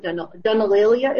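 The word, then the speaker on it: Dunaliella: I'm not, you introduced me to that because I hadn't heard of Dunalilia, is how you say it.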